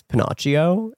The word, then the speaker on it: Pinocchio: And then my other friend had heard me say Pinocchio and was like, actually, the proper Italian pronunciation is Pinocchio.